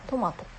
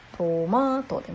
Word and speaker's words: トマト: 標準語ではトマトって上から下がっていくトマトってね、上から下がっていく発音なのかなと思うんですけど、関西では、私は関西人なのでトマトって言います。トマートでマーが高くなるんですね。